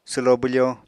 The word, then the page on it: swlo bwfo: apatani